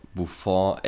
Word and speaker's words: Buffon: Du hast den gerade, du hast gerade den äh, Buffon echt ein bisschen zu französisch ausgesprochen. Ich mach's mal deutsch.